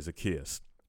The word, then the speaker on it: Zacchaeus: On multiple occasions, Jesus told the rich, either the rich young ruler or Zacchaeus, am I saying that right? – Yeah, I've heard Zacchaeus. – Okay, Zacchaeus. He